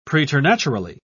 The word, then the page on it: preternaturally: British English